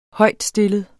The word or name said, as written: højtstillet